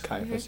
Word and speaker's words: Caiaphas: Well, anyway, they were just fantastic as Judas, and Josiah Zelke as Caiaphas. – Caiaphas.